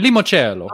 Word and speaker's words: limoncello: Is it limoncello or limoncello? How do you pronounce it? – If you're Italian, limoncello.